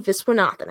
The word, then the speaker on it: Viswanathan: Margaret Qualley, Geraldine Viswanathan.